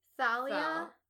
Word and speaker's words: Thalia: So wait, it's pronounced as in thing or thought and not that or though. Thing, though. Thing, thought, that, though. Okay. – No, I don't get it. – It's like thing Thalia, as instead of thought, Thalia.